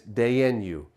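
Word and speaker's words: Dayenu: There's a word that is pronounced in different ways, but one of the ways it's pronounced is deinu.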